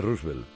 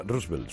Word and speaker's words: Roosevelt: Pero es que además, si alguna vez habláis de Roosevelt, no se pronuncia Roosevelt, sino Roosevelt.